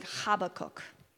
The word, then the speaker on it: Habakkuk: I like Habakkuk. If you say the name in Hebrew, I think it's like Habakkuk, if you want the proper pronunciation there,